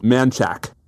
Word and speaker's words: Manchaca: But there's also, my favorite is there's a street that's spelled Manchaca, but that's pronounced Manchak.